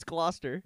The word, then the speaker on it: Gloucester: I pronounced their team the Gloucester Stripers. – Yeah. – But he's like, dude, it's Gloucester. – Yeah, that didn't make any sense. The spelling behind that.